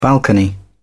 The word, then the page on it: balcony: British English